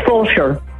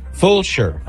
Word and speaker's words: Fulshear: How do you say the name of the town? – It's Fulshear. – Fulshear.